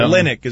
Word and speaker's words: Olynyk: How do we pronounce his name? It's Olynyk, right? – Kelly O. – Kelly O. – I've heard Olynyk and Olynyk. – Yeah. Olynyk is what it is.